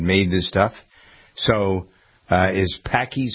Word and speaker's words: Paqui: This is pronounced Paqui, P-A-Q-U-I.